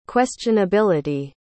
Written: questionability

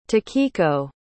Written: takiko

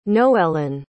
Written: noellyn

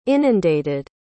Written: inundated

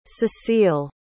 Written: cecille

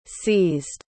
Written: seized

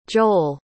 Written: joel

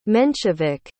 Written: menshevik

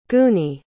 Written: gooney

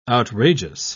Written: outrageous

